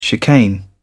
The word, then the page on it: chicane: British English